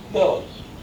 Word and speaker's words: Milnes: It's just Mill with an N. – Milnes. – Milnes. – Milnes. All one syllable.